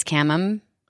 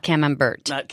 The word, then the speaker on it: camembert: I think the one word in the cheese world that so many people mispronounce, but is the one that can show that you kind of know anything about cheese, is camembert. – Yes. – Not camembert.